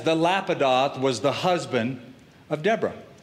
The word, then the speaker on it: Lapidoth: The description is, now Deborah, a prophetess, the wife of Lapidoth. Now, I know that's a weird name for a dude, right? Some of you are thinking that, Lapidoth. The Hebrew pronunciation is Lapidot.